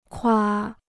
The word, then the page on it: 夸: kuā